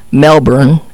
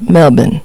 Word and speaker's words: Melbourne: And it's not Melbourne. It's Melbourne.